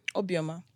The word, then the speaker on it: Obioma: Obioma.